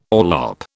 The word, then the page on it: orlop: British English